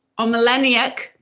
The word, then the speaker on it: Omelianuk: We have Scott Omeleniuk.